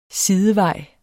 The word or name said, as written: sidevej